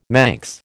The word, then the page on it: Manx: American English